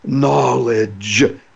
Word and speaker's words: knowledge: So people say to me, knowledge is spelled with a K in it. Yeah, but it's pronounced without the K.